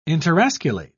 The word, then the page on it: interosculate: British English